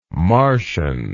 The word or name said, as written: Marcion